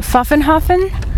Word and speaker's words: Pfaffenhofen: This evening we have a concert in Pfaffenhofen. Is that correct pronunciation? – Yes, that was great.